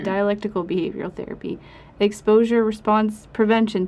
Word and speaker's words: dialectical: I say dialectable. Can you help me out? – Oh, I mean, you're not terribly off, just dialectical.